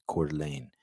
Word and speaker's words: Coeur d'Alene: That's how you pronounce it, Coeur d'Alene.